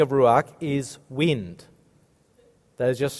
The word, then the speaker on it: Ruach: And now, I'm no expert at Hebrew pronunciation, but you've got to get a good guttural going. Ruach. Have a go at that. Ruach.